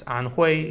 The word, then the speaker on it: Anhui: The city is spelled H-E-F-E-I, Hefei, and it's located in Anhui Province. Anhui is spelled A-N-H-U-I.